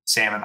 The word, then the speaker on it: salmon: Salmon. – I'm pretty sure it's salmon. Yeah. Okay. The L is silent and the A is kind of not an A.